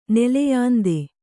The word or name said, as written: ನೆಲೆಯಾಂದೆ